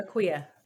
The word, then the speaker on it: Akua: I want to make sure I'm saying it right. Am I saying it correctly? – Yes, Dr. Aquia.